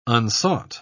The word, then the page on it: unsought: British English